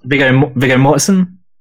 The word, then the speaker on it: Viggo Mortensen: Viggo Mortensen.